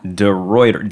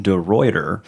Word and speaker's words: DeRuiter: Did I say that right? – No. – How do we say it? That's okay. – It is okay. I didn't learn to say it till I was 12. Okay. It's DeRuiter. – So DeRuiter. Yeah. DeRuiter.